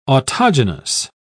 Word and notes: autogenous: British English

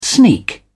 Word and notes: sneak: British English

